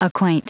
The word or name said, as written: acquaint